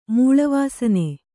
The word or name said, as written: ಮೂಳವಾಸನೆ